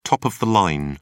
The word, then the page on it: top of the line: British English